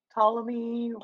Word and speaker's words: Ptolemy: Burnham also said that when Deputy Minister Palemi, I think the T is silent. – Minister Ptolemy.